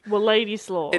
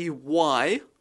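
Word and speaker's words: Władysław: This is going to be very difficult to pronounce, but it's Wladyslaw Sklodowski. – Wladyslaw. – It is Wladyslaw.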